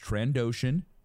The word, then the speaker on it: Trandoshan: Um, I mean uh, he's he's trans he, Trandoshan. Say it right. I said it right. He's Trandoshan.